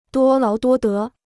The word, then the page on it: 多劳多得: duō láo duō dé